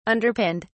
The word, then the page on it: underpinned: British English